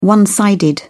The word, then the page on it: one-sided: British English